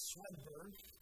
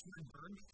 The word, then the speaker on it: Swedberg: We are Mark and Anita Swedberg. Someone asked how to pronounce her name. It's Swedberg. It's a Swedish name, but it's Swedberg.